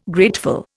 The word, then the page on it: grateful: Indian